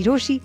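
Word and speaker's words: Roshi: मेरा नाम है मिर्ची रोशी, नाम की स्पेलिंग है ROCHIE.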